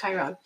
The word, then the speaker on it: Chiron: I think it's Chiron. – Oh, very good. Yes, that's how you say it.